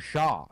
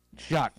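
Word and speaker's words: Shough: is it Shock or Shaw? I always forget. – Tyler pronunciation. – Shock.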